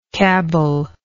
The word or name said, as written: cabell